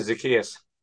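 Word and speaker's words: Zaccheaus: Yeah, Zacchaeus.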